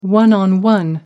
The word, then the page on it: one-on-one: American English